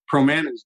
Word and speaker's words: Promanas: Is the founder, president of, pronounce it for me. – Promanas Group. Rhymes with banana.